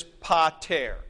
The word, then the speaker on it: pater: So this word is pater. It is not P alpha tau eta rho.